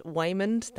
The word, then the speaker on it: Waymond: Her husband, Waymond, that is how you say his name, by the way, he's about to divorce her, that she's getting audited by the tax office.